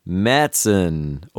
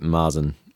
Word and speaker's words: Märzen: But yeah, so what I'm making is a Märzen, or some people pronounce it Marzen. – Marzen, Märzen. – Yeah. It's an A with an umlaut, so it should be Märzen.